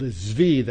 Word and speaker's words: Zvi: There's also a video that Friends of Israel produced in 98 called Zvi, that's spelled Z-V-I, Zvi colon, The Return.